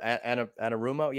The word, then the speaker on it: Anarumo: But what a phenomenal job Lou Anarumo or Anarumo. Yeah, that's how you pronounce it.